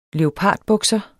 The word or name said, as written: leopardbukser